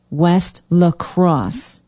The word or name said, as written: west la crosse